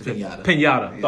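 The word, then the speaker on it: piñata: Panada, I think I'm saying that right. Or Pin, well, I think it's Panada. – It's Pinata. – Thought so. Okay. Because they had the, yeah, the little squiggly line. Accent.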